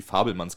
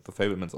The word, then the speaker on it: Fabelmans: Bei, bei äh, Fabelmans spielt, oder Fabelmanns, ich weiß nicht, irgendwie im Deutschen höre ich öfter mal, dass, dass die Fabelmanns genannt wird, aber auf Englisch wird er halt Fablemans ausgesprochen.